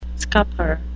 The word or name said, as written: scupper